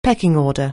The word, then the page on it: pecking order: anglais